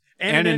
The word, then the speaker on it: anonymity: I never can pronounce this word anonymity an, anonymity anonymity, anonymity sound it out.